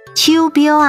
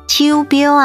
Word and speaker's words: 手表: 我的智慧型手表可以记录心跳次数，手表台语念作秋标啊，秋标啊，客语念作速贝尾，速贝尾。